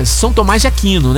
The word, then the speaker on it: São Tomás de Aquino: A Gabriela da Fazenda Três Marias, em São Tomás do Aquino. É, São Tomás de Aquino, né? Aí, sim. Agora falei certo.